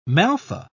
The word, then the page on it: maltha: British English